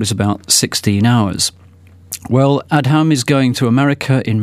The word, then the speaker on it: Adham: Adham, that's it's Adam spelt with an H in it, so it's actually Adham, tries to beat records for visiting tube stations.